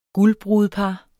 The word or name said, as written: guldbrudepar